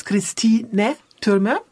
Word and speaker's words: Christine Trümer: In German it's Christine Trümer.